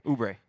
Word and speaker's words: Oubre: Oubre. That's how you say his name.